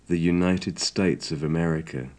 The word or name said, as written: the united states of america